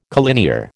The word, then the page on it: collinear: American English